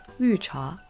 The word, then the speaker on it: 绿茶: In Chinese, we pronounce this as 绿茶.